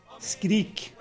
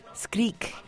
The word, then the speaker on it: Skrik: How do you say the scream in Norwegian? – Skrik. – Skrik. – Skrik, yeah. You should start to learn Norwegian because you take all the words immediately.